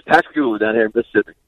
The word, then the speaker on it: Pascagoula: I was trying to figure out how to say the how do you say where you're coming from? Is that Pascagoula? – Yes, sir. Yes, sir.